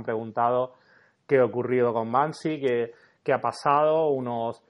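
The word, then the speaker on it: Banksy: Bansi.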